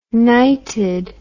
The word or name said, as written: nighted